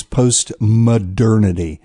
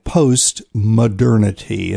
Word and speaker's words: post-modernity: I think I pronounced that post-maternity. No, it's post-modernity. M-O-D-E-R-N-I-T-Y, post-modernity.